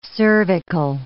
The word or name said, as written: cervical